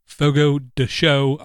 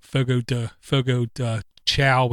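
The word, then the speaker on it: Fogo de Chão: 11 of us went to Fogo de Show. I was told is the correct way to pronounce it, where I used to call it Fogo de Chow, which is basically a carnivore's dream come true.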